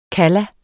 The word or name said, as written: kalla